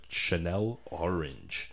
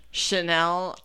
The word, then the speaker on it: Channel Orange: It was actually the first single off of his debut album, Channel Orange. – Yeah. Channel Orange. – Orange. – Jesus Christ. – Orange. – Chanel.